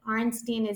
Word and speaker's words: Arnstein: Yeah, my Twitter is Vicky, V-I-C-K-Y. Arnstein is A-R-N-S-T-E-I-N.